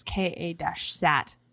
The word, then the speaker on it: KA-SAT: And this warning comes after the KSAT. I hope I'm saying that correctly. It's KA-SAT, Network of U.S.